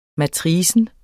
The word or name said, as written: matrix